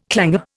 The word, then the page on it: clanger: Indian